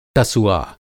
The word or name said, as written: टसुआ